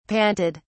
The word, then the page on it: panted: British English